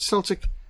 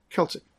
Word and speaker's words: Celtic: And by the way, if you pronounce it Celtic and you are in Northern Ireland or Scotland, they will correct you and say, no, it's Celtic. It might be spelt with a C, but it's pronounced with a K.